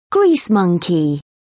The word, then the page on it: grease monkey: British English